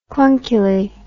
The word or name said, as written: clunkily